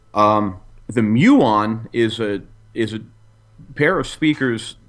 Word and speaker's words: Muon: I'm probably gonna butcher the name. Muon. – The Muon is. Yeah,